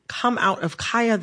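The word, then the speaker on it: Khia: I am going to, in this conversation, pronounce Kaya, the rapper, with the long I. Kaya, okay? My neck, my back, for those who need a refresher. Kaya, okay? Mm-hmm. The Kia Asylum is a mispronunciation of her name.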